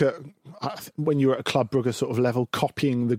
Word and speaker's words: Club Brugge: Club Brugge, or Club Brugge, as it's actually pronounced.